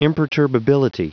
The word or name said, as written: imperturbability